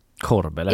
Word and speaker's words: Kårböle: Men typ Kårböle. – Kårböle. – Det är min uppfattning att man säger.